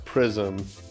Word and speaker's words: PRXSM: That was, I think it's pronounced Prism, P-R-X-S-M, with a track called Can You Tell Me?